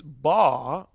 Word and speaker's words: Por: Her name's Ba. You would pronounce it phonetically B-A-W, but it's spelled P-O-R.